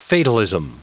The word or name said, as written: fatalism